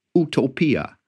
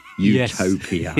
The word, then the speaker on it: Utopia: Incidentally, while we're on the Italian pronunciation, can I just point out that they all say Utopia, not Utopia.